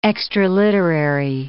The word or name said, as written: extraliterary